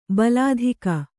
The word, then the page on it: ಭಲ್ಲಾತಕಿ: Kannada